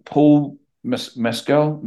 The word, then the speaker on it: Paul Mescal: That film, After Sun, Paul Mescal, is that how you say his name? – Yeah.